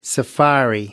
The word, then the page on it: safari: American English